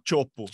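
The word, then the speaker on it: Teahupo'o: Chopo. – Chopo. Mas se escreve Teahupo, por isso que eu falei, tá?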